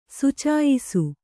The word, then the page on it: ಸುಚಾಯಿಸು: Kannada